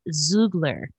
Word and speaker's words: Xoogler: So the school created by these ex-Google employees named, I believe it's pronounced Zoogler. – Zoogler School. Zoogler School. How do you spell that, though? – Okay, so it's spelled X-O-O-G-L-E-R. – Oh, like ex-Googler. Okay.